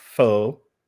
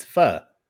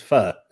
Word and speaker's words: pho: No, it's not pho. It's pho. The correct pronunciation is pho. Trust me on that.